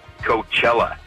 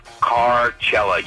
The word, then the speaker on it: Coachella: But I got to join the pronunciation police department and say, you idiots, keep mispronouncing Coachella. It's Carcella, you dumbasses.